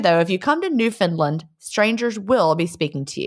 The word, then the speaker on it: Newfoundland: Just to help you start, it's not Newfoundland. It's pronounced Newfoundland. I almost did it, right? I was saying Newfoundland. Mm-hmm. I was putting the D in there, though.